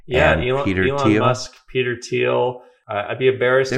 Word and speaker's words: Thiel: So if you look, it's Peter, I think it's spelled T-H-I-E-L, but it's pronounced, I think, Thiel, like T-E-A-L. – Correct.